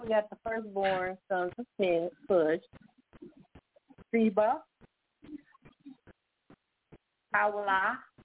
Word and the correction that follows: Havilah: Always correct me if I'm saying it wrong. – Havilah. – Say it again. – Havilah. – Oh, it's a V sound.